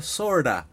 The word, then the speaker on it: sort of: Sort of, que es sort of, que se pronuncia sorda, como si no escucharas, eres una sorda.